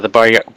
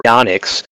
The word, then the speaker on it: Baryonyx: Baryonyx, correct me if I'm wrong, if I'm saying that wrong, but the Baryonyx... – Baryonyx.